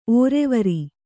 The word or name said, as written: ಒರುವಾರ